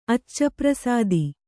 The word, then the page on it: ಅಚ್ಚಪ್ರಸಾದಿ: accaprasādi